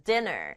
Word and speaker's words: dinner: dinner. n이 두번 있지만 dinner 이렇게 발음하지 않아요. 영어에서는 똑같은 글자 두번 오면 같은 소리잖아요. 소리 한 번만 내요. 그래서 dinner 아니고 dinner.